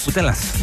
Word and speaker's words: Putelas: Putelas.